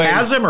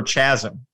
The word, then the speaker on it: chasm: But anyway, chasm or chasm? – It's hard C sound there.